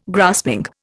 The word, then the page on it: grasping: Indian